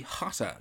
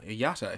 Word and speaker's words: Jota: Yeah, it would be Jota, Yata, if he was Spanish, but he pronounces the J because he's Portuguese.